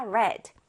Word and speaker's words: read: 하나 주의해야 할 것이 read, 읽다의 과거형은 철자는 똑같이 적어주는데 발음이 달라집니다. read라고 해요. 읽었다, read.